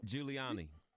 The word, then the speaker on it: Giuliani: Giuliani.